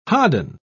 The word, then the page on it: hodden: British English